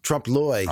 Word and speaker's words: trompe-l'oeil: Trompe-l'oeil.